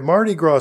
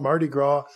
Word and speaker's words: Mardi Gras: Mardi Gras is French. In its spelling, I looked up how to pronounce Mardi Gras in French, and of course, it's the S is not pronounced, and Mardi means Tuesday, and Gras means fat.